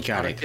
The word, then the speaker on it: Kotick: We also learned that Bobby, how do you say Kotick? – Bobby Kotick.